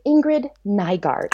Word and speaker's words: Ingrid Nygaard: Is that how you say her name? I've always wondered. – Oh, yeah. It's Ingrid Nygaard. – I do say it right. Yeah. Okay. Woohoo. I was like, I am so butchering the name, but I'm not.